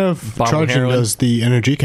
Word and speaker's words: Energica: Yeah, it's so the bike is Energica. It rhymes with America. Just change the C to a G. You got it. – Energica.